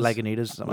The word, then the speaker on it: Lagunitas: I feel, and also I think I'm saying their name wrong, because I'm saying it like Lagunitas, which would be, isn't it Lagunitas? – Lagunitas. – Lagunitas. Lagunitas. Like that, yeah. Yep.